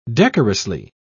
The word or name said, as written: decorously